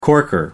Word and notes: corker: American English

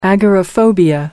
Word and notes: agoraphobia: American English

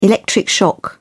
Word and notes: electric shock: British English

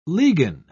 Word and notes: ligan: British English

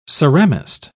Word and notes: ceramist: British English

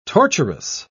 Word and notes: torturous: British English

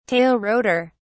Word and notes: tail rotor: British English

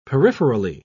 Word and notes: peripherally: British English

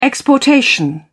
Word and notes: exportation: British English

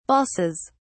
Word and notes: balsas: British English

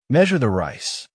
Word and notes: measure the rice: American English

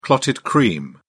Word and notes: clotted cream: British English

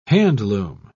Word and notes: handloom: British English

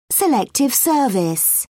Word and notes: selective service: British English

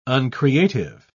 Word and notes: uncreative: British English

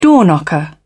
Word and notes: door knocker: British English

